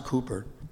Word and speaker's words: Cowper: Hymn writer William Cooper, looks like Cowper, but it's pronounced Cooper.